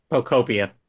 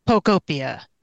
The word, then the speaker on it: Pokopia: I rolled credits on I can never say it right. Pokopia? Pokopia? – Pokopia. – Pokopia.